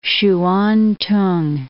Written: Hsuan-t'ung

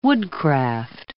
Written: woodcraft

